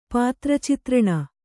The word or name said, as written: ಪಾತ್ರಚಿತ್ರಣ